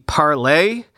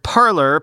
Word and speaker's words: Parler: As I also said on the weekend episode, are we sure it should be pronounced Parler? Shouldn't it be parlay, which means to speak or to talk in French?